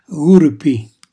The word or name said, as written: gurpi